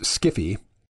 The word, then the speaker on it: sci-fi: I think most sci-fi, pronounced skiffy, has very little interest in the future and is basically just fantasy adventure with some futuristic or otherworldly trappings.